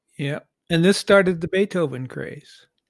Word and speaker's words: Beethoven: And I don't want to embarrass you right on the podcast, but it's pronounced Beethoven.